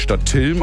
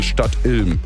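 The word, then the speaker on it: Stadtilm: Ein Kollege hat eben gesagt, es wird nicht Stadt Tilm ausgesprochen, sondern Stadt Ilm.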